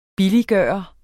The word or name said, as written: billiggøre